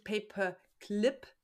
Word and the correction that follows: Pay-per-Click: sondern Pay-per-Click.